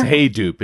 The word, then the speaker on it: Hadoop: Kevin Klein said, Hadoop. I don't even know how to say it. That's what's embarrassing. – It's Hadoop.